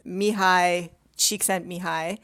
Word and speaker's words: Mihai Csikszentmihalyi: Well, I can tell you the scientist that I mentioned in the piece, Mihai Csikszentmihalyi. – You nailed that. Wow. – Yeah, it's kind of complicated how to pronounce his name.